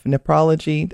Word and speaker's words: nephrology: And as a doctor your associate professor of medicine in the division of nephrology.